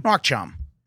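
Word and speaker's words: nước chấm: Nguoc cham, which is an amazing Vietnamese marinade that's really all-purpose.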